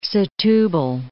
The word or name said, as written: Setubal